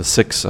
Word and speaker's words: Sikhs: You know, the Punjab state, India, the Punjabi people, the Sikhs, or the Six, as you would pronounce it more accurately,